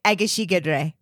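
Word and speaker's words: Egészségedre: I remember you look at the person, you say, Egeshigedre.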